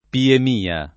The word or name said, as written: piemia